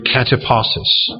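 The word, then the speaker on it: katapausis: The word rest here is translated from the Greek word kataposis.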